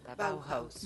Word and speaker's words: Bauhaus: Bauhaus.